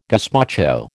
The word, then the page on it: gazpacho: American English